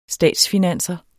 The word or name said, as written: statsfinanser